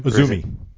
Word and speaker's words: AZM: Is that how you say it? AZM? – Azumi. – Azumi.